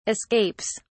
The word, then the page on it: escapes: British English